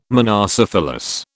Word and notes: monocephalous: British English

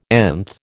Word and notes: nth: American English

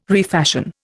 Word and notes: refashion: Indian

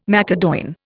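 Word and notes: macédoine: Indian

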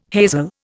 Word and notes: hazel: Indian

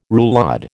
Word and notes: roulade: American English

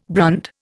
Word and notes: brand: Indian

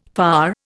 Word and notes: far: Indian

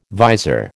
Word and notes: vizor: American English

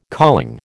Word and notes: calling: American English

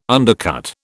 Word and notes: undercut: British English

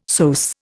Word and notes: psoas: Indian

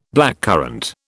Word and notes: blackcurrant: British English

